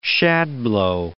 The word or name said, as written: shadblow